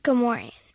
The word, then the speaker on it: Gamorrean: Gamora is a different one. – Gamorrean. – It's Gamorrean. Okay.